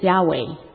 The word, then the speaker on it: Yahweh: The pronunciation is Yahweh.